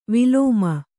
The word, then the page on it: ವಿಲೋಮ: vilōma